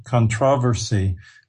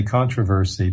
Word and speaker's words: controversy: You were right on track, and really, it's just that you've kind of survived the, how do you say it in Britain, controversy. We put an accent on the different syllable there, so we would say controversy.